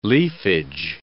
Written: leafage

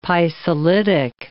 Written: pisolitic

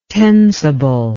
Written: tensible

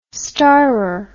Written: starrer

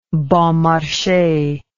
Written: bon marche

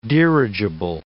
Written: dirigible

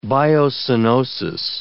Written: biocenosis

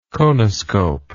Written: conoscope

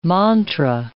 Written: mantra

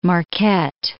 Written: Marquette

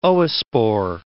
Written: oospore

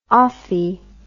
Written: ophi

